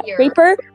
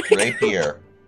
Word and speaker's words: rapier: I will use my I don't know how to pronounce it. – Raper? – I'm gonna call it Raper. – Rapier. – I won't call it. – It's a rapier.